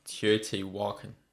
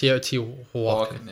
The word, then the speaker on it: Teotihuacan: I would imagine that might be Teotihuacan pronounced. – Teotihuacan. – Is it Huacan? – Yes. – Teotihuacan.